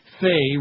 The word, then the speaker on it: Fay: Fay, rhymes with gay,